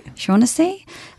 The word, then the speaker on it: Shaughnessy: Shaughnessy.